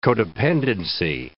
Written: codependency

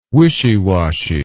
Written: wishy washy

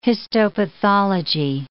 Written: histopathology

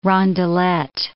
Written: rondelet

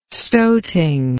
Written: stoting

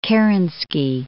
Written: Kerensky

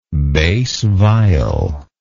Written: bass viol

